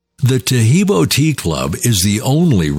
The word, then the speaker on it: Tahibo: Tahibo is spelled T-A-H-I-B-O. E-E-B, like boy, O, then the word T, and then the word club.